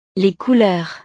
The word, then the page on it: couleurs: frances